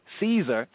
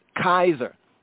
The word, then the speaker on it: Caesar: So we would not have said Julius Caesar, for example, right? And the C was not pronounced as the S sound. We would have said Julius Kaiser. Uh-oh. Julius Kazar. Uh-oh. Julius Kaiser. That's how his name would have been said in the time.